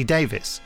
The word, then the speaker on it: Davies: But then Russell pronounces his name Russell T. Davis. So my rule of thumb is that you should pronounce someone's name the way they pronounce it.